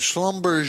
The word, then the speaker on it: Schlumberger: This is a – some call it Schlumberger, but it's Schlumberger is how you pronounce that.